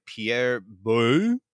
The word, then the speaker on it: Pierre Boulle: This was printed in 1963 by Pierre Boulle. – Yep, that's how it's said. – I don't speak French. I took Spanish. Pierre Boulle.